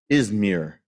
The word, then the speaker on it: Izmir: You can hear the name Smyrna in its modern name, Izmir. I guess that's how you pronounce it. Is that how you pronounce it, Jay? You were probably there. Yeah. Jay approves of that.